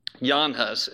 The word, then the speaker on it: Jan Hus: I want to show you something because you might not have ever heard of John Huss, Jan Hus, as some pronounce his name.